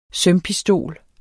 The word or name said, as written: sømpistol